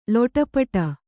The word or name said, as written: ಲೊಟಪಟ